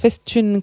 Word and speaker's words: festum: Alors, j'ai dit Festum, en fait, c'est Festune.